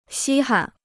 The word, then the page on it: 稀罕: xī han